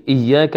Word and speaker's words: إياك: هل الياء الساكنة من كلمة إياك ننطقها بقليل من الضغط على الفم أو بسلاسة ورفق؟ لا، بسلاسة ورفق، هذه